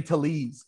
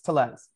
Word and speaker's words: Tellez: I've been saying Tellez. It's Tellez. Yeah.